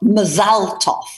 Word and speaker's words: Mazal Tov: I'm so glad you said Mazel Tov and not Mazal Tov, which apparently people is the correct way to say it.